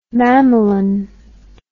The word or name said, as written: mamelon